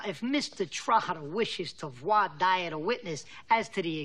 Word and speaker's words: voir dire: Voir dire.